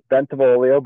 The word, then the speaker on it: Bentivoglio: Oh, that pronunciation is a little off, but I'll really? Take it. And thanks for the intro. It's Bentivoglio, but I mean, it was Bentivoglio? – Yeah, perfect. – The G is silent.